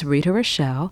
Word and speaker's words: Rita Rochelle: My name is Rita Rochelle, and that's spelled R-I-T-A. Rochelle is R-O-C-H-E-L-L-E.